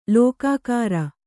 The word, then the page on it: ಲೋಕಾಕಾರ: lōkākāra